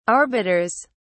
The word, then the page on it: arbiters: British English